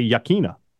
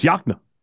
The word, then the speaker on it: Yokena: Subdivision outside of Vicksburg that is spelled Y-O-K-E-N-A. And a lot of people say Yakena, but it's not. It's Yakna.